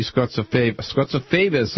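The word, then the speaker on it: Scozzafava: There's a story this morning that Didi Scotsifavis, that the Post says that's how you pronounce it. I'm not sure, but that she's lost her perch now in the Republican Party.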